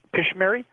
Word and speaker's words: Pishmeri: Pishmeri. It's P-I-S-H-M-E-R-I, and that's pigeon, New Guinea pigeon.